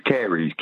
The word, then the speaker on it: caries: The word is caries, C-A-I. – No. Nope. Can I pronounce it for you? Yes, it is caries. Caries. Oh, yes, caries.